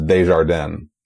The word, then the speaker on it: Desjardin: I believe the actual pronunciation there is Desjardins.